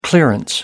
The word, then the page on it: clearance: American English